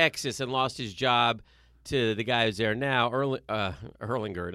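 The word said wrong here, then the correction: Ehlinger: Now I'll get his name wrong. – Ellinger.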